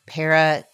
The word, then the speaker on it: parakaleo: I said in the first week that there's a Greek word, parakaleo. I want to make sure I'm saying it right.